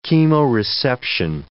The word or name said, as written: chemoreception